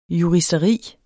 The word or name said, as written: juristeri